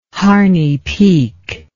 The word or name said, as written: Harney Peak